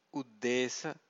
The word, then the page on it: uddesa: Pali